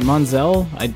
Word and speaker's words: Monsell: Monsell. – You pronounced it right the first time. Yeah, okay. – With an S.